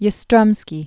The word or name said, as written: Carl Yastrzemski